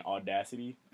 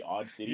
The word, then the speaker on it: Audacity: It just keeps saying Audacity, and it not be Audacity, and it be Odd City.